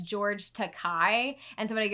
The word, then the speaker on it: Takei: Takei.